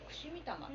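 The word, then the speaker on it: 奇魂: 跡の魂と書いて串見玉って読むん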